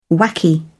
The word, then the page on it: wacky: British English